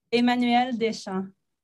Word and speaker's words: Emmanuelle Deschamps: What, how would you, how do you say your name in your accent? – Emmanuelle Deschamps.